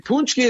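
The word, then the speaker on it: pączki: And it's spelled, if you want to Google, where can I buy a Punchki, or search, where can I buy a Punchki today? P-A-C-Z-K-I. Of course, that's how you spell it.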